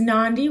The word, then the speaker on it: Nandi: My name is Nandi, rhymes with Gandhi.